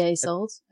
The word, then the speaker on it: Isolde: Yeah, Isolde.